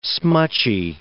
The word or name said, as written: smutchy